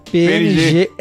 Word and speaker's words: PNG: A pronúncia correta de PNG é PNG.